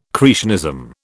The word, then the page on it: creationism: British English